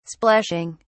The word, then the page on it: splashing: British English